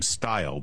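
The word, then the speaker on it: Steil: It's too bad that the congressman from Wisconsin pronounces his name Style.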